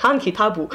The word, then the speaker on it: Han Kitab: So he wrote this book called, and they say it, the way they pronounce it is like Han Kitabu.